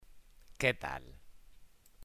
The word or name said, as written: ¿que tal